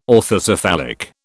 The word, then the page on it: orthocephalic: British English